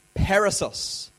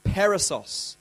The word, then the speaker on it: perissos: and the word is actually pronounced perissos, perissos, as in the two S's are double, peri, double S-O-S, perissos.